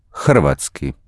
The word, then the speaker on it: hrvatski: Sometimes it's sandwiched between two consonants and used as a vowel, as in hrvatski.